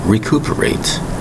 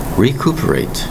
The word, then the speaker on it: recuperate: Today's word, recuperate. Recuperate. Four distinct syllables. Spelled R-E-C-U-P-E-R-A-T-E.